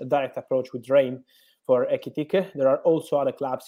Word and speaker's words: Ekitike: Firstly, Fabrizio, can you give us the latest on Newcastle's interest in the Rem striker, Ekitike? I hope I pronounced that right. – Yes, the pronunciation is correct.